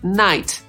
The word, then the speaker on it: knight: Podsumowując, angielskie słowa noc i rycerz wymawiamy tak samo, czyli knight, pomimo różnej pisowni, a więc są homofonami. Na początku słowa rycerz jest jeszcze dodatkowa litera K, która jest nie ma.